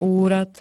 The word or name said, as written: úrad